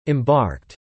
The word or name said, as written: embarked